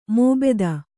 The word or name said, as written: ಮೋಬೆದ